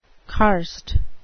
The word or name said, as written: karst